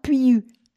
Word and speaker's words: Puiu: Alors attention, ce n'est pas facile à dire. P-U-I-U. Comment on prononce ça? Piiu. Pas facile, hein? Kristi Piiu, qui est un réalisateur, scénariste, acteur roumain.